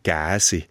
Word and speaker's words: Gäsi: Geschrieben kommt das Gäsi, aber die ortsübliche Aussprache ist Gäsi.